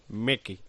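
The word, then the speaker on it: Meeke: Decía Fernando, por favor, cuando habléis del abandono del que iba líder en el Tour de Corse, no lo pronunciéis como Mickey, como si fuera Mickey Nadal, que no es así. – Es Mec. – Mecky.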